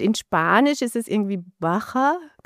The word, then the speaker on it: Baja: Es ist nicht so schwer. Also Baja, Trabajas, also gibt es verschiedene Wörter, wo ein J vorkommt und die werden alle einfach wie CH ausgesprochen.